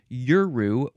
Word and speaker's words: Yuru: Their word is Yuru, Y-U-R-U.